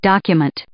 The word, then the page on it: document: noun